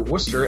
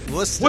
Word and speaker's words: Worcester: Ah, well, since we last talked, Hershey went 2 0 with an overtime win over Worcester and a regulation win over Bridgeport. Did I pronounce that correctly? Because they don't spell it the way you're supposed to say it, apparently.